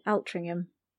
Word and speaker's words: Altrincham: Salvation Army and Altrincham is your friend. – Interesting pronunciation of Altrincham. – How did I say it? – Altrincham.